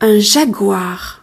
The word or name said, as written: jaguar